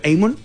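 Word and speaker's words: Hammond: Ah, bueno, se pronuncia Amon.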